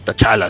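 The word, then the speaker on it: Ta Lo: Según yo, se pronuncia Shala porque la T era silenciosa. Pero en, en, el MCU y en muchas otras partes ya le dicen tachala, así, chala.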